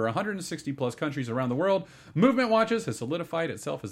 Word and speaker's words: MVMT: MVMT watches, pronounced movement, if you were wondering. They took the vowels out. It's pronounced movement watches.